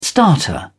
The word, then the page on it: starter motor: British English